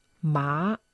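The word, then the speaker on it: Ma (马): Während Ma im dritten Ton Pferd bedeutet.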